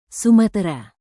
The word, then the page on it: sumatera: Indonesian